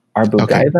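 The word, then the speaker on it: Arbugaeva: This is how you pronounce her name. Arbogaiva.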